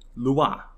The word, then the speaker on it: Loire: And also in France, their longest river, the Loire. I hope I'm saying that right. Loire. Actually, hold on. Let me get the Google Translate real quick. Loire.